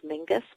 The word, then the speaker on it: Menzies: I mean, in my youth, I read a lot of work by someone named Heather Menzies. Actually, apparently the Scottish pronunciation is Mingus, but no one says it that way.